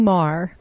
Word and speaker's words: Marr: Michael Wutini Marr. I guess it's pronounced just like Bill Maher.